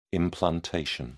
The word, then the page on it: implantation: British English